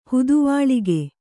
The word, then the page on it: ಹುದುವಾಳಿಕೆ: Kannada